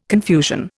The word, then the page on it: confusion: Indian